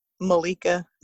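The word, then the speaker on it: Ma_Leigh_Ka: So Instagram is the best place to find me, and my name on there is Malika, but it's spelled like how you would pronounce it.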